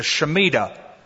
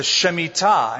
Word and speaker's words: Shemitah: If you prefer the American pronunciation, the Shemitah. But it's the Shemitah.